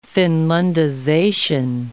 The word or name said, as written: Finlandization